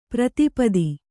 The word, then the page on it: ಪ್ರತಿಪತ್ತು: Kannada